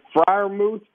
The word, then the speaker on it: Freiermuth: And so I've had a chance to see, am I pronouncing his name right? Is it Freiermuth? – Yeah.